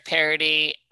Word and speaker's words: Paradis: I've never heard your last name pronounced before I watched your video, the one we were just talking about, the Ink Master Superfan video of Derek Paradis. And I thought it was Paradis the whole time.